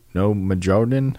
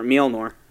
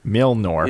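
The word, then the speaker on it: Mjölnir: No Majordan? How do you say his hammer? – Oh, that's his hammer. Mjolnir. – Mjolnir.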